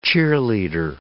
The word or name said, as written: cheerleader